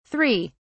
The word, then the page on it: 3: British English